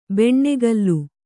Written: ಬೆಣ್ಣೆಗಲ್ಲು

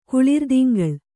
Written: ಕುಳಿರ್ದಿಂಗಳ್

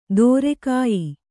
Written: ದೂರಗೆಯ್ಯು

